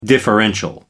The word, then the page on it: differential: American English